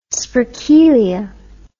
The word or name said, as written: sprekelia